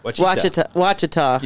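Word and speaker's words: Ouachita: That's when I got lost in the Wachita Mountains. – The Wachita. Wachita. – Yeah. – It's spelled like O-U-C-H. O-U-A-C-H-I-T-A.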